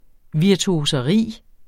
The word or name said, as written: virtuoseri